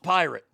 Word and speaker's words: PYRT: It's P-Y-R-T, but it's pronounced pirate. I don't know where in the world it's pronounced pirate, but they're saying it's pronounced pirate, P-Y-R-T. That is not pronounced pirate.